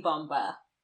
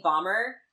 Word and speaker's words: bomba: And I have to say it, like, instead of saying bomber, I have to say like Bomba because like that's how it's said in the musical when he has his song.